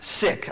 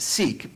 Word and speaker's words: Sikh: I think it's actually pronounced Sikh. I grew up pronouncing it Sikh, but S-I-K-H.